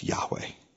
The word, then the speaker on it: Yahweh: If you don't know what Jehovah is our translation of the Hebrew name of God given to Moses, which is more accurately pronounced Yahweh.